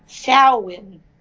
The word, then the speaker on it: Samhain: It's pronounced Samhain, but it's spelled S-A-M-H-A-I-N.